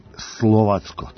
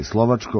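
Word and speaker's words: Slovácko: U grupi H će, pored crno-belih, biti Keln, Nica i Slovacko. Tako se pravilno izgovara, mi možemo da kažemo i Slovačko, ali u pitanju je ekipa iz Češke.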